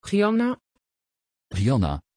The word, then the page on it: gianna: Dutch